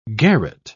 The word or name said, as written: garret